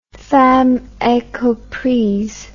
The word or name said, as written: femme incomprise